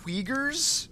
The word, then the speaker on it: Uyghurs: And it happens that this one very remote, reclusive tribe called, I hope I'm pronouncing this right, the Uyghurs? – Yeah, that's right.